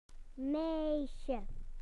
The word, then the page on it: girl: British English